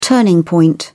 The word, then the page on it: turning point: British English